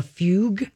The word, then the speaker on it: fugue: But it's very, very rare for someone to have what they call a fugue state. Am I saying that right? – I think so, yeah. – F-U-G-U-E.